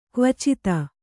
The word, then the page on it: ಕವಚಿತ: kavicita